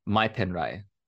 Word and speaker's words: mai pen rai: So she said the word, um, and I'll say it here, uh, just so that way, like you all can kind of hear how it's pronounced. So it's mai pen rai right so that's the word and I,